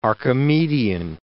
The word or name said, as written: Archimedean